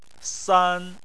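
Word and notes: san: tone 1

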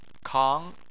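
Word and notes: kang: tone 2

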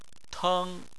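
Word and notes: teng: tone 1

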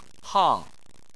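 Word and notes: hang: tone 4

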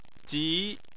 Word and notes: ji2: chinese

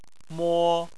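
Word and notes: mo: tone 1

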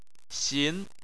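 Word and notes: xin: tone 2

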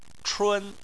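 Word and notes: chun1: tone 1

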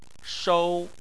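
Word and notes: shou: tone 1

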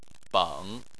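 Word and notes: beng: tone 3

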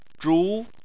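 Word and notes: zhu: tone 2

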